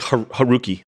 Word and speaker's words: Haruki: Harumi Murakami? – Oh, Murakami. You mean the guy, the author who wrote the story? – Yes. – Yeah, I think it's like Haruki Murakami. – Oh, Haruki Murakami, but he wrote the original story, and the movie's by who?